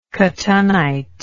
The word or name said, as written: cotunnite